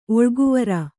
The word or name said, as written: ಒಳಗುವರಿ